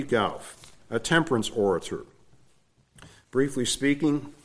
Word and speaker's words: Gough: Gough. I looked up the pronunciation, G-O-U-G-H.